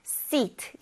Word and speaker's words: seat: 근데 이 좌석, 자리를 의미하는 seat, seat는 seat, 이렇게 길게 발음한다는 거 잊지 마세요.